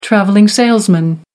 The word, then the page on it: travelling salesman: American English